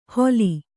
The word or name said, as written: ಹೊಲಿ